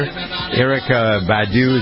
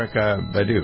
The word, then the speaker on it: Erykah Badu: Track list includes everything from jazz to pop, hip-hop, soul, Latin, tracks by Roy Ayers, Madonna, Stevie Wonder, Erykah Badu, is that how you pronounce the name? – Yeah. – Erykah Badu, right?